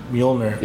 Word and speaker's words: Mjolnir: I never pronounce this right. Major? – Mjolnir. – Mjolnir. – Yeah. – That's how you say that? – That's how you're supposed to say it. I don't think the J is really, it's pretty silent.